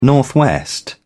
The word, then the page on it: northwest: British English